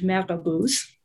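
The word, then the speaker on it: marabout: At the moment in the region, there are figures who are called in French marabouts, right? Which is based on a kind of French colonial mispronunciation of the Arabic murabbet.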